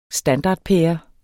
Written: standardpære

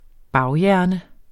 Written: baghjerne

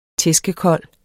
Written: tæskekold

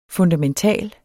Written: fundamental

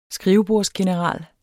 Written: skrivebordsgeneral

